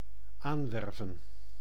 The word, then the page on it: embaucher: France; [ɑ̃.bɔ.ʃe]; /ɑ̃.bo.ʃe/